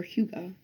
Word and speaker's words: Hygge: Um, this word is spelled H Y G G E, but it's pronounced Huga or Huga. I think there's a couple of other ways, but anyway, it is a Danish word, and I just Googled it.